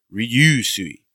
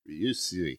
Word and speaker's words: Ryusui: So it's S-E-I-R-Y-O-U-I-N. Mm-hmm. I want to say Seiryoyin. – Seiryoyin. – And then... Ryusui. So it's R-Y-U-U. So it's the word, the sound that I can't actually do because I'm American. And then S-U-I. So it's like Ryusui. – Ryusui.